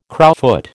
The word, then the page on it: crowfoot: American English